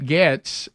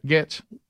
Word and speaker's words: Gaetz: And Matt Gaetz, is that how you say his name? – Yeah. – Gaetz.